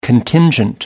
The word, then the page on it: contingent: American English